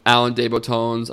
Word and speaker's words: Alain de Botton: Did you ever read Alain de Botton's, I'm definitely saying that wrong, The Architecture of Happiness? – I have, and I think you're saying it right.